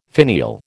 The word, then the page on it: finial: American English